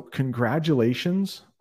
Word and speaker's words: congratulations: So congratulations. Try this for congratulations. Con-grad. I know there's a T in congratulations. We pronounce it as a D.